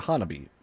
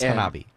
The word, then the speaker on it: Hanabi: It is H-A-N-A-B-I. – All right. Hanabi or Hanabi? – I think it's Hanabi. – Hanabi. All right, Hanabi. H-A-N is a Nancy. A-B-I. Hanabi.